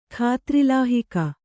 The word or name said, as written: ಖಾತ್ರಿಲಾಹಿಕ